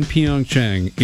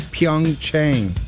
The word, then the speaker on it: Pyeongchang: Tinder use in Pyeongchang is up 1,850%. – Pyeongchang. It's Pyeongchang.